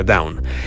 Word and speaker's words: Doane: Down se escribe como Doane, pero entiendo que se pronuncia Down.